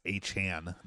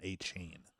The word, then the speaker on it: Achane: Especially even Devon Achane, apparently he said that everybody was saying his name wrong. It's Achane, not Achane.